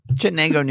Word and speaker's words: Chittenango: Chittenango, New York.